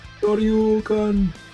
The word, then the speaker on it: Shoryuken: Shoryuken. – The Shoryuken, an uppercut imbued with energy.